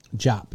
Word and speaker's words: Diop: Khalifa Diop? Their second pick? – Khalifa Diop. – Jop. – Sagana, remember Sagana Jop? – Yeah. – That's how you pronounce it.